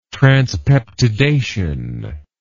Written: transpeptidation

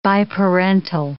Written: biparental

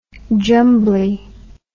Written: jumbly

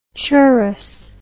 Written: churrus

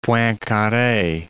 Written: Poincare

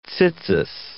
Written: tzitzit

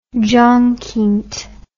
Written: jongkind

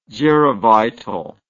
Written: gerovital

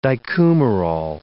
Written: dicumarol

